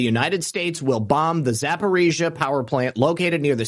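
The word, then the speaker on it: Zaporizhzhia: Zaporizhia.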